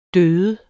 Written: død

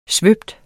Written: svøbe

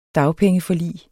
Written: dagpengeforlig

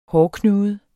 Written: hårknude

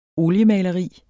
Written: OLIEMALERIERNES

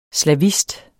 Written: slavist

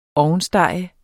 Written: ovnstege

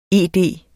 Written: ED

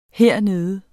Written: hernede

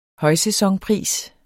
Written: højsæsonpris

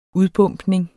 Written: udbombning

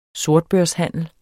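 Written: sortbørshandel

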